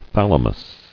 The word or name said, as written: Thalamic